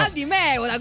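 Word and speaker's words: Al Di Meola: Allora ti prego però, Aldi Miola e John McLaughlin, ti prego mai. – Dimmi come si pronuncia, no davvero. – Aldi Meola, come dicono tutti, John McLaughlin.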